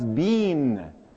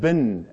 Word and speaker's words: been: B as in boy, E-E-N as in Nancy. And I shot back, oh, that's bean. And that's the way the Canadians say it. That's the way the British say it. That's the way the South Africans say it. And Uncle Chet turned to me and said, I'm sorry. In the United States, that bin, as in B-I-N, I've been there.